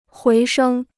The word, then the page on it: 回升: huí shēng